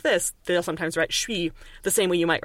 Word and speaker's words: chuis: Um, but then sometimes this even gets respelled C H U I S, shui, um, because that's what it sounds like. The S at the end is silent because it's always silent.